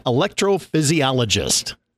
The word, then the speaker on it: electrophysiologist: So Dr. Suzanne Figovsky, and she is an electrophysiologist. Did I say it right? – You did.